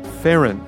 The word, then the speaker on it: Farron: Ah, so the Fun Palace sound designer's surname is pronounced Farron.